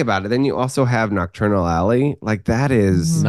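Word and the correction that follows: Knockturn Alley: Sorry, I said nocturnal. Nocturnally. I knew I was mispronouncing it the moment it came out of my mouth.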